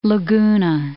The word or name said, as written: Laguna Beach